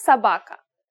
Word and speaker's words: собака: We read this Это собака. Remember how O can be pronounced as a soft A? In this case, we pronounce both O's as A.